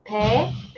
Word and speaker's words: Pae: So my Korean name is pronounced Pae is the bay.